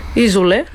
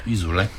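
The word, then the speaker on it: Isole: Няма да имаме време за Изол или Зорле. – Изоле. – Изоле, да, ударението ми се ми обегна, но пък те ще бъдат първата група на втората вечер от вашия Metal Weekend.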